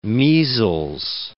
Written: measles

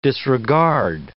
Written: disregard

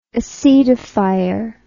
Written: acetifier

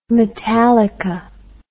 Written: metallica

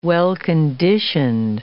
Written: well-conditioned

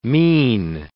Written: mesne

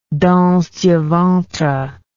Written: danse du ventre

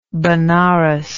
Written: Banaras

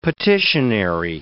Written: petitionary

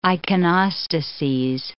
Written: iconostases

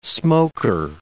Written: smoker

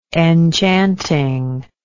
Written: enchanting

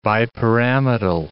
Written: bipyramidal

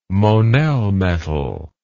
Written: monel metal

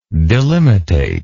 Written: delimitative

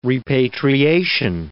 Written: repatriation

